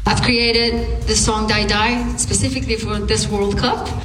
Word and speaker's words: Dai Dai: Not Die Die, it's spelled D-A-I-D-A-I, so not Die Die as in die. Die Die is the name of the song,